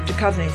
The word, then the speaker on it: Duchovny: And on Jared's Culture Show, starting at 2, it's a best-of episode featuring David Duchovny. I think I said that right. – Duchovny.